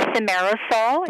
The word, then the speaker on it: thimerosal: Can you speak to the connection between autism and thimerosal? – It's thimerosal is the correct pronunciation.